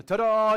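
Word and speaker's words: ta-da (תודה): Ta-da, almost like a magician. Ta-da, you know, something happened. But it's that pronunciation, ta-da, the Hebrew language, and it means this when you see praise.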